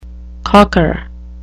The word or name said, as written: cocker